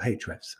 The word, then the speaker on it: Ahrefs: who are Ahrefs, or Hrefs as they're pronounced properly.